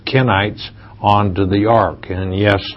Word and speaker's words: Kenites: And then you said, and the Canaanites, and you spell it with a C, it's not Canaanites that we're saying, Kenites, K-E-N-I-T-S, are of the tribe of Cain.